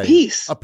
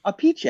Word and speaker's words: Appice: You know, he's Carmine's brother, and they pronounce their last names differently. – Vinny Appice, the drummer. – Yes. – And Carmine, how does he say? – A piece. And apparently their father pronounces it, or he did pronounce it, a piche.